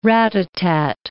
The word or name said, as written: rat-a-tat